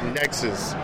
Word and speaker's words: Nexuiz: Nexuiz.